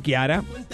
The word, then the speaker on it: Chiara: La CH se pronuncia como la Q. – Claro, claro, Chiara. Chiara, sí, señor.